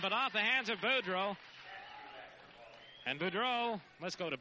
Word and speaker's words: Boudreaux: Carlson Boudreaux, spelled with the X. B-O-U-D-R-E-A-U-X. Boudreaux, Boudreaux, Boudreaux, however you want to pronounce it. It's Boudreaux. Fulmer gets the basket, has a chance for the three-point play.